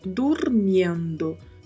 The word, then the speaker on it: durmiendo: dormindo tendo bastante cuidado com a pronúncia do R com o som de vibrato e de pronunciar claramente o yendo. Então falamos dormindo, dormindo.